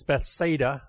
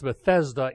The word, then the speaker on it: Bethsaida: I want to talk a little bit more about Bethsaida, and I do mispronounce it because it's Bethsaida in the north, and it's Bethesda in Jerusalem, the pool where Jesus, the waters were stirred.